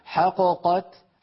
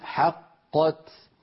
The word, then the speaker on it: حقت: لو قلقلت الأولى لم فك الإدغام، يعني لو قلنا حققت حققت فانفك الإدغام وصار كأننا نطقنا بقافين منفكتين، وهذا لا يصح أبدا، لابد أن نقول حقت.